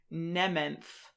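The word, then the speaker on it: Mnementh: And now that I have seen it, I would pronounce that name Nementh. Like, not pronounce the initial M. And then that, second syllable has an N before the TH that was kind of getting elided.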